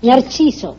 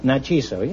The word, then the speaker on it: Narciso: How do you say it? – Narciso. – Narciso. Yes, yeah.